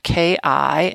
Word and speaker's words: ki: Because Reiki is an intention-focused channeling of ki, spelled K-I and pronounced ki as in lock and key,